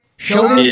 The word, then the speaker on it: Chauvin: How do you say his last name? Chauvin? – Yes.